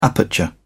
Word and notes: aperture: British English

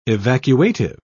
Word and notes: evacuative: British English

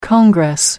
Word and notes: congress: American English

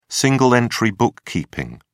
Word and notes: single-entry bookkeeping: British English

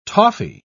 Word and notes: toffy: British English

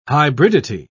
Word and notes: hybridity: British English

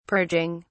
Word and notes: purging: British English

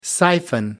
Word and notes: siphon: American English

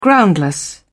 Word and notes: groundless: British English

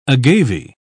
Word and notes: agave: British English

